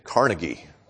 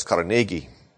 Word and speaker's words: Carnegie: Most of us American-speaking people say Carnegie, but it's Carnegie.